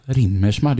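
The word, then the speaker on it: Riemersma: Rimmersma, det är så man säger. Jag har försökt uttala det på rätt sätt. – Ja, för jag säger nog... För det stavas med IE. Jag får nog sagt Rimmersma.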